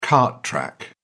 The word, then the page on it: cart track: British English